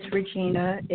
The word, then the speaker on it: Regina: Yes, my name is Regina. It is spelled R-E-G-I-N-A.